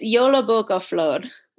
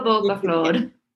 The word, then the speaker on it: Jólabókaflóð: And I'm going to insert the actual pronunciation, I think, in the audio. – It's Jólabókaflóð. – I looked it up. Jólabókaflóð. – Yeah, Jólabókaflóð. – Perfect. Yes, Jólabókaflóð. It is super fun.